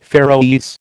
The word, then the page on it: Faeroese: American English